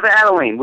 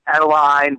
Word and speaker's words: Adalene: It is Adalene. We do get Adele, Adeline, but it's Adalene.